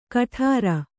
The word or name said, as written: ಗಡುರ